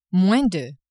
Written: moins de

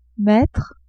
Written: mettre

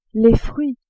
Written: fruits

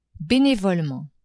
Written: benevolement

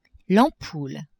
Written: ampoule

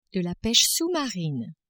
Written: peche sous-marine

